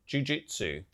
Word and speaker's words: Jujutsu Kaisen: Jujutsu Kaisen. Did I say that right? – An anime? Wait, did you just say Jujutsu? Jujutsu. Yeah. – I was miles off, you are Kaisen. Right.